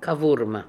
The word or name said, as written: Kovurma